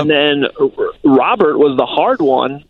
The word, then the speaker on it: Robert: That is the official pronunciation that the White Sox are giving us now. It's Robert.